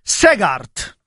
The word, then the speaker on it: Segaert: Comunque, Tarossi, quello che ha vinto oggi si chiama Alec Segaert, è un belga fiammingo. Quindi si dice, si scrive Segaert e si pronuncia Segaert.